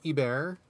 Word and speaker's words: Hebert: I mean, I would say something like Ebert, something like that. Is that how it is? Or that's close. It's so for people at home, it's spelled H-E-B-E-R-T, which you might think maybe Hebert if you don't know anything else.